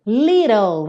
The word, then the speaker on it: little: Você pega o T e transforma em rarara. Fez o rarara, little, L, língua no céu da boca, ok? E o E? O E não é pronunciado, como quase sempre, certo?